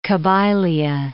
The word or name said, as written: Kabylia